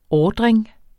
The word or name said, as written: ordring